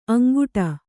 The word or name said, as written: ಅಂಗಾಡಿ